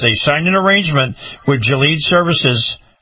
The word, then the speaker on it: Gilead: And they are going to be using, here it is, I believe I'm saying this right, Gilead Services, G-I-L-E-A-D. Maybe it's Gilead.